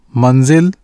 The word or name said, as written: منزل